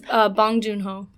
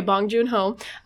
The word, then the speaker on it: Bong Joon-ho: Bong Joon-ho. – Okay, Bong Joon-ho.